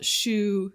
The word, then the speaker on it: choux: And it's spelled choux, C-H-O-U-X, not choux like the shoe you wear on your foot.